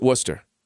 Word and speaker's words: Worcester: So Worcester is spelled like Worcester, like Worcester. – So it's not like it sounds. – Yeah, but they pronounce it Worcester.